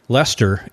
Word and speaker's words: Leicester: Yes, because in England, it has to be an English name, because there's a city in England, and you spell it L-E-I-C-E-S-T-E-R. And they pronounce it Leicester. – Leicester, yeah. – So it looks like it should be Leicester, but they just say Leicester.